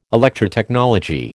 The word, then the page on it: Electrotechnology: American English